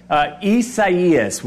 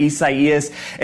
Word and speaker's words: Isaias: Isaias, which I believe is another way of saying Isaiah. So again, Isaias.